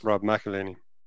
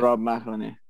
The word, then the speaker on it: Rob McElhenney: Rob McElhenney. – That's how you say it. – Rob McElhenney, yeah.